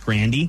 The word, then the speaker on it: Grande: Ariana Grande said her name isn't Ariana Grande. It's pronounced Grandy.